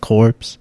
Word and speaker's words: Corps: So recently, I went into the Conservation Corps. It's called pronounced Corps because it's French.